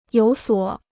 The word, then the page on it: 有所: yǒu suǒ